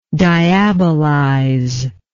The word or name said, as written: diabolization